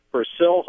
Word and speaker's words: Persil: Persil, however, and I heard the name pronounced in the ads, which they don't run anymore, but they pronounce it Persil.